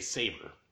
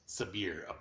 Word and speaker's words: Sabïre: Because my head wants to say Saber. – Right. – But it's Sabïre, according to the main dude.